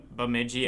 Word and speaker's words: Bemidji: Bemidji.